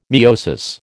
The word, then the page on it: miosis: American English